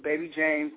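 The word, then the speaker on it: Baby Jaymes: A lot of people spell my name wrong, so it's Baby James, baby spelled B-A-B-Y, James, J-A-Y-M-E-S.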